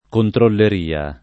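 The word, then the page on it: controlleria: italiano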